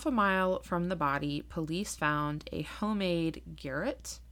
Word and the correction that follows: garrote: Um, I thought it was um, a garrote. – Garrote. I knew I was going to say it wrong. – I even listened to someone say it because I listened to a podcast about this. Ah, damn it. Okay, homemade garrote. It is made from two sticks and a thin piece of wire.